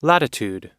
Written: latitude

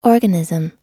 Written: organism

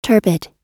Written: turbid

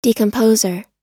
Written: decomposer

